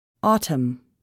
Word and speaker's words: autumn: The letter N is not pronounced following M at the end of a word. Autumn, column.